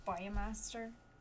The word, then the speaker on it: Baumeister: I don't know how to pronounce Baumeister. – Oh, it's pretty close. – Yeah. – Baumeister. – Oh, perfect.